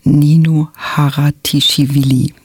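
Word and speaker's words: Nino Haratischwili: Und heute spreche ich über das achte Leben für Brilka von Ninu Haratischvili. So spricht man sie nämlich.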